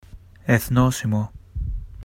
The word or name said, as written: Ethnosimo